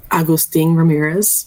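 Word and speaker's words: Agustín Ramírez: Hey, let's ask how to pronounce that name. – Four Nations Fig? – No. Which one? – Agustin Ramirez. – Agustin Ramirez. – There you go. Yep. Look who was correct again.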